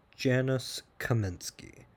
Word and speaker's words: Janusz Kaminski: And it's this with the cinematographer, Jamis Szymanski. Let me pronounce his name properly. It's Janusz Kaminski.